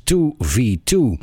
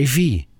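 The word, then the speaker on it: IIVII: En ik vertelde hem, of ik vroeg hem eigenlijk, hoe spreek je deze bandnaam nou eigenlijk uit? En toen keek hij me aan en toen zei hij, Ivy. Nou, dat heb ik dus altijd verkeerd uitgesproken. Altijd 2v2 gezegd, omdat ik gewoon weg en niet wist hoe je het uitsprak.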